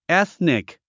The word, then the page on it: ethnic: éθnik; エスニック